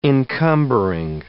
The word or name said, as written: encumbering